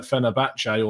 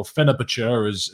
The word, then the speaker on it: Fenerbahce: But I think we've seen from his performances at Fenerbahce, or Fenerbahce, as Hugh Izzy will tell me it's actually pronounced, I don't think it would have changed too much in the grand context of things.